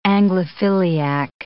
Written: Anglophiliac

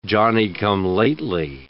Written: Johnny-come-lately

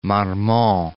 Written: Marmont

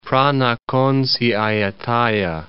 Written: Phra Nakhon Si Ayutthaya

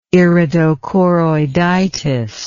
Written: iridochoroiditis

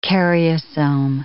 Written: karyosome

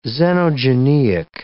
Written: xenogeneic

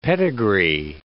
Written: pedigree

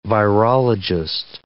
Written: virologist

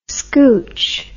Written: scooch

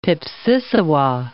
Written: pipsissewa

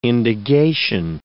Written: indagation

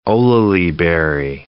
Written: olallieberry

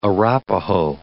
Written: Arapahoe

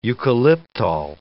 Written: eucalyptol

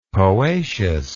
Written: poaceous

